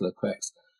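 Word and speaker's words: Le Queux: I mean, the irony about the founding of the service is that Lequex, I'm never quite sure how to pronounce his name, but I think it is correctly pronounced Lequex,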